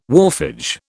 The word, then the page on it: wharfage: British English